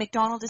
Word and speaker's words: McDonald: And McDonald is spelled M-C-D-O-N-A-L-D.